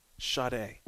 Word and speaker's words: Sade: Is it Sade? – It ain't Sade, bro. Sade. – What is it? – Sade.